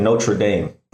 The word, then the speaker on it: Notre Dame: You know, I heard one of the former presidents of Notre Dame say the first part is the French pronunciation. The second part is anglicized. So he said Notre Dame.